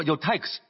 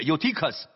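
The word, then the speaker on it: Eutychus: 我们聚会的那座楼上有好些灯烛，有一个少年人名叫尤推古，坐在窗台上，发音是 Utik，我知道有些人说 Utix，但其实是 Utikus，困倦沉睡。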